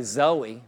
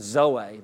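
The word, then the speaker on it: Zoe (ζωή): They say Zoe, though. But the actual pronunciation is Zoe. The E is pronounced as a long A, Zoe, and it means the life and the nature of God.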